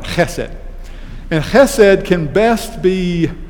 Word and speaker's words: chesed: And that word is chesed. And I'll spell it for you. It is spelled E-S-E-D. Now, C-H-E-S-E-D is the way we would sort of transliterate that. Chesed.